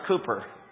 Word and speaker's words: Cowper: That C-O-W-P-E-R is pronounced Cooper.